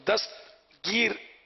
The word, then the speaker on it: دستگیر: اور دستگیر یہ درست تلفظ، اور دستگیر ٹین تگیر یہ غلط تلفظ،